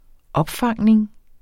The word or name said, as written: opfangning